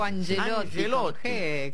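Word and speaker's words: Ancelotti: Ahora, Angelotti no se escribe con C, pero lo pronuncia con G. Sí, dijo Angelotti. Con G.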